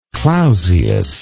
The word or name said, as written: clausius